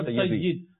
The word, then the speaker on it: Sayyid: But Amir al-Sunnat, you know, he says that Sayyid is the proper pronunciation. Sayyid means someone who should follow. – Who should. – And Sayyid means someone who should be followed. – Indeed. – So they are the Sayyid, not the Sayyid.